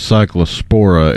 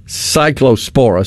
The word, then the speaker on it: Cyclospora: So yeah, the parasite is Cyclospora. It causes cyclosporiasis. – Okay, Cyclospora. So I had the accent in the wrong place, which that's one of those. – The wrong emphasis on the wrong syllable.